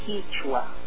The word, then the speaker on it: Kichwa: And but now the in Ecuador, and I think I'm not sure in South America and the whole Andes, but we spell it Quechua, K I C H W A, Quechua. But it's pronounced Quechua.